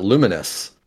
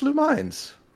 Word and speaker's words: Lumines: I was like, "No, it's Lumines." I've always called it Lumines. I have too. And then I, I, when I was looking it up on, on Wikipedia here, and it, it has on there, it says pronounced luminous.